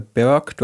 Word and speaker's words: Berg: Also ich heiße Bernd Eishold, Künstlername Berg. Du hast es schon recht, richtig ausgesprochen. Also ich spreche es halt auch Englisch aus.